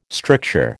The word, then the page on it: stricture: American English